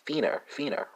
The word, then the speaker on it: finner: It's going to be fina, spelled F-I-N-N-E-R. Norwegian speakers, you know that your task is simple here because it's the same for both languages, the spelling that is, but it's pronounced fina, right? Spelled F-I-N-N-E-R, right? So slightly different pronunciation, the same spelling.